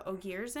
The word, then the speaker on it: Ogier: And there's also Ogiers in it, spelled like ogre, but pronounced Ogier.